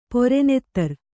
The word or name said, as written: ಪೊರೆನೆತ್ತರ್